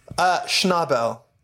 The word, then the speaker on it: Schnabel: Schnabel.